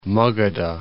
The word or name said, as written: Magadha